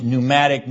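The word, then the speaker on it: pneumati (πνεύματι): Now the second phrase underneath en Christo is en, once again, means in, transliterated en, and then p-n-e-u-m-a-t-i, pneumati, which you can see if they spell it P-N-E-U-M-A-T-I, looks like pneumatic, and that's exactly what it would come over in English to be, like a pneumatic drill,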